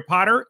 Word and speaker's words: Potter: My name is Jerry Potter. Yes, it rhymes with the boy wizard.